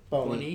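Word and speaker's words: Bony: Is that how you say his name? – Boney. Yeah, Wilfred Brown Boney.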